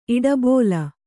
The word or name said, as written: ಇಡಬೋಲ